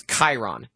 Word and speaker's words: Chiron: Yeah, well, and the CH sound in and the spelling in Greek is a K sound. So the CH, like the trainer of heroes in Greek mythology, is Chiron, not Chiron.